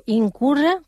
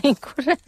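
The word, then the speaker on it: Ibn Qurra: Bueno, también nombraste a otro matemático, Incurra, creo que lo dije bien, Incurra se llama. – Sí, perfectamente, perfectamente.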